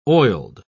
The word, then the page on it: oiled: British English